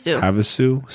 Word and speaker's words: Havasu: Havasu City.